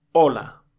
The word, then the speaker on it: hola: The first thing we said was hola. Hola is written with H, but this H is silent, okay? It doesn't have any sound.